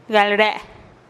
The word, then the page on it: gal re-e: TdVZ